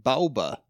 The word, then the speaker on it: Baoba: B-A-O-B-A. How would you say that name? – B-A-O-B-A? – Baoba.